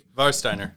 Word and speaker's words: Warsteiner: Warsteiner. – I mispronounced the German W.